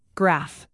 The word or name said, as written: graph